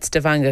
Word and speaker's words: Stavanger: That was our kind of equivalent of Stavanger, the big sort of oil, North Sea oil company, oil city in the UK. – I'm glad you said Stavanger, because now I know I've been pronouncing it wrong.